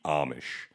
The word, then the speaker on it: Amish: My childhood is lived on the eastern shore of Maryland on the Pennsylvania border, basically neighbors with the Amish, or as you say, Amish, which is not how you say it. It's Amish. So we're clear. It's Amish.